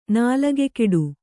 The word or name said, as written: ನಾಲಗೆಕೆಡು